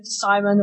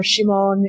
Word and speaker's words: Simon: In one village, there is a young male digger named Simon, or Shimon, if you're going for the direct Japanese pronunciation.